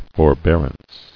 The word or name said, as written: Forbearance